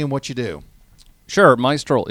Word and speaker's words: Maestral: now roll your R. Yeah, Maestral. Maestral. Maestral. Maestral. Oh, she says it the best.